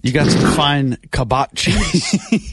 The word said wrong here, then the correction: Cabot: Apparently, it's pronounced Cabot. – No, because it's Cabot.